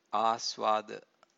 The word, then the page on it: asvada: Pali